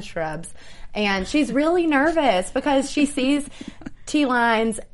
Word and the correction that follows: T-Lights: and... – T-Lights. – Why do I keep saying that? T-Lights. – It's like Snoop Lion. – Oh, yeah. I gotta say it like T-Lights.